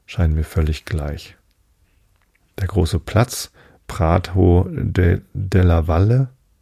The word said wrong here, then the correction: Prato della Valle: hätte ich beinahe Spanisch ausgesprochen, Della Valle. Nee, es ist Italienisch, Prato della Valle, hoffe ich.